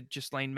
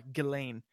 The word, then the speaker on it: Ghislaine: And, you know, people think that we're catching on to this with the Ghislaine Maxwell and fucking, or sorry, Ghislaine.